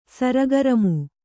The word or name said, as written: ಸರಗರಮು